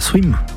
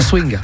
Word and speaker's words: swim: Est-ce que je l'ai bien prononcé là, swim? – Oui, très bien. – Pas swing. – C'est pas swing.